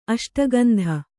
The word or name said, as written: ಅಷ್ಟಗಂಧ